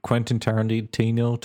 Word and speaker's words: Quentin Tarantino: Which reminds me of trivia of that story that people should watch about Quentin Tarantino talking about.